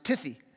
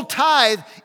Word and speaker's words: tithe: he pronounced it Tiffy. Uh, you bring the tithy. Uh, obviously, he hadn't been to Sunday school very much, but so you bring the tithy. So bring the whole tithe into the storehouse.